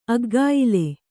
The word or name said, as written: ಅಗ್ಗಾಯಿಲೆ